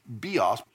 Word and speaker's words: bios: He could have used any of these three that are behind me, bios, or pronounced actually bios.